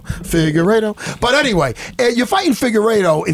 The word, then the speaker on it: Figueiredo: Davidson Figueiredo.